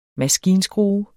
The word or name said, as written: maskinskrue